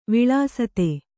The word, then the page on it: ವಿಳಸಿತ: viḷasita